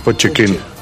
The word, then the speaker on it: Pochettino: We are enjoyed by the head coach, Mauricio Pochettino. – Pochettino. – Pochettino.